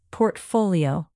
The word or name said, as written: portfolio